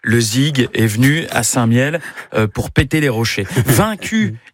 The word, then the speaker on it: Saint-Mihiel: Pour remercier Saint-Michel, la ville adopte son nom, mais mal prononcé. – Saint-Mihiel.